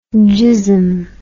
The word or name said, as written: gissum